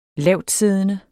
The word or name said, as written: lavtsiddende